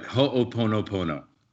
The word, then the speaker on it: Ho'oponopono: It's Ho'oponopono. – Pretty close. No one says it right. Ho'oponopono is how to say it.